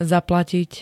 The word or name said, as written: zaplatiť